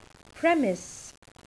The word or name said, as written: premise